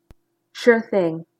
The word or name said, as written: sure thing